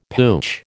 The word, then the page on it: pooch: American English